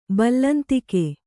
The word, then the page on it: ಬಲ್ಲಂತಿಕೆ: ballantike